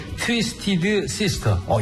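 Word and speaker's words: Twisted Sister: 이 발음이 좀 디피컬트합니다. 좀 읽어주시죠. – 트위스티드 시스터. – 역시 앵커 출신은 다 있고 맞습니다.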